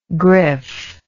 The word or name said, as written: griffe